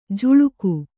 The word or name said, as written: ಝುಳುಕು